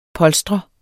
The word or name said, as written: polstre